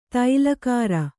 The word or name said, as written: ತೈಲಕಾರ